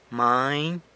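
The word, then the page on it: many: San Lucas Quiaviní Zapotec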